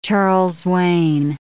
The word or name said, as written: Charles's Wain